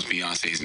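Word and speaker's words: Beyoncé: A lot of black people feel like they don't trust you because you mispronounce Beyonce's name. Have you learned to say it correctly yet? – Well, you just said it correctly. Yeah. Beyonce, right? – Are you going to stand with African-American people?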